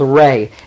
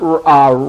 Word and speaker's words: Re: had this god called Re. It is not Ra, people say Ra. It is not Ra, it's Re. And I found that out this year. It is Re, long A.